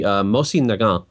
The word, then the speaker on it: Mosin Nagant: And it is actually impossible to pronounce this name correctly in any one language, but the correct pronunciation would be Mosin Nagant.